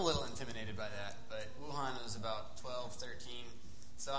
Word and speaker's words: Wuhan: But the A in Chinese, it's like you pronounce like ah. So Wuhan, it's like W-U-H-A-N, it's Wuhan. Shanghai, S-H-A.